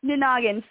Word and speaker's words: Nanagin: Can you tell us where Keegan was born, and where is she living at the start of, you said Nanagin? How do you pronounce it? – Nanagin. So a great way to think about it is if you're saying no noggin with a bit of a southern accent. Nanagin.